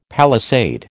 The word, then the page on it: palisade: American English